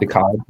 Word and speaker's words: DeKalb: Uh, DeKalb, DeKalb, how do you say it? De- yeah, DeKalb, DeKalb, DeKalb. Okay, yeah.